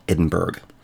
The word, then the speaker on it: Edinburgh: Now, I said Edinburgh. You will never find Edinburgh on the map if you look for it like that. It's what we actually call Edinburgh. B-U-R-G-H in Scottish is pronounced Borough.